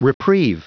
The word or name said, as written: reprieve